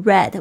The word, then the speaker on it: read: 大家特别注意一下，这个 read 要改成 read，为什么呢？因为是过去过去式，它的发音是不一样的哦。